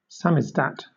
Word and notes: samizdat: British English

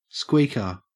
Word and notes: squeaker: Australian English

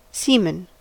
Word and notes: semen: American English

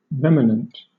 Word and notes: remanent: British English

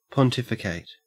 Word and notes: pontificate: Australian English

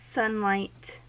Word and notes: sunlight: American English

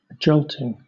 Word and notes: jolting: British English

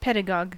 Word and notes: pedagogue: American English

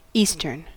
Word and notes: eastern: American English

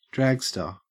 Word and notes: dragster: Australian English

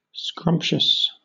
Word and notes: scrumptious: British English

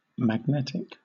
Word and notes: magnetic: British English

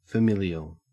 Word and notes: familial: Australian English

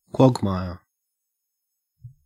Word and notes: quagmire: Australian English

